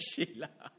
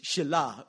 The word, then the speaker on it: Shelah: And his name is, and I don't know how you pronounce this right, but it's Sheila. But it's not Sheila. But it's Shela. Let's just say Shela, right? Okay, S-H-E-L-A-H, right?